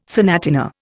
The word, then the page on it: sonatina: Indian